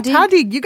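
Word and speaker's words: tahdig: How do you pronounce it, Samin? – Yeah, tadig. You got it. You're doing a good job.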